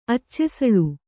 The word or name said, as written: ಅಚ್ಚೆಸಳು